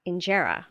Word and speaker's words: injera: What I've been trying to add to my repertoire is injera.